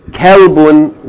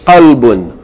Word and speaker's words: qalbun: You don't want to use kalbun when you intend qalbun. Qalbun, yani heart, right? Kalbun means a dog.